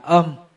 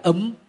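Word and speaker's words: Om: Án một chữ cũng là một câu thần chú, thì nguyên văn chữ Phạn đọc là ôm. Án Mani Bát Di Hồng đó đọc là ôm Mani Bát Mê Hum, là chữ ôm, hay chúng ta đọc theo dân gian tức là ấm.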